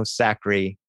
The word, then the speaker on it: Sacre: Antonio Sacre.